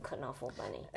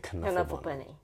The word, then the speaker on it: Knuffle Bunny: Knuffle Bunny 大部分会这样讲，可是如果你对作者比较认识，知道 Mo Willems 的他是哪里人的话，你就会念成 Knuffle Bunny。 – And if you listen to their audio of him and his daughter Trixie reading the book,、 mm-hmm. they say Knuffle Bunny。